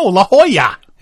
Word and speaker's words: La Jolla: I looked at it and thinking, oh, La Jolla, La Jolla, California. No, La Jolla.